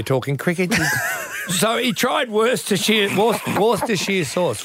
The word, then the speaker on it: Worcestershire: Celebrated my 50th over the weekend and tried Worcestershire sauce for the first time. My life will never be the same again. Is that how you say it? – Worcestershire. Like Leicestershire and Northamptonshire.